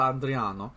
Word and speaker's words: Adriano: You gotta pronounce it like that, Hotel Adriano, which is a beautiful hotel off the Italian coastside.